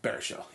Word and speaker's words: Baruchel: Baruchel, yeah.